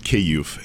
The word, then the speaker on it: Kyiv: Yeah, it's pronounced Kyiv. Kyiv. Kyiv. And they pronounce it Kyiv, yeah. – Which, you know, is a sharp dagger in my back every time they say Kyiv. But it's better than the Russian version of Kiev.